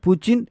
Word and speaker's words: പുചിൻ: പല പത്രങ്ങളും ആംഗല സ്പെല്ലിംഗ് നോക്കി പുടിൻ, പുട്ടിൻ എന്നൊക്കെയാണ് എഴുതുന്നത്. റഷ്യൻ ഭാഷയിൽ പുചിൻ എന്നാണ് ഉച്ചാരണം. പക്ഷേ പത്രങ്ങളിൽ അങ്ങനെയൊരു ഉച്ചാരണം കാണുന്നില്ല.